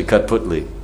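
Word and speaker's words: kathputli: Because sometimes I say put cutli, but it's actually cut putli.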